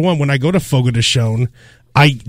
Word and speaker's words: Fogo de Chão: Well, I call it Fogo de Chão, but it's actually pronounced... – Fogo de Chão.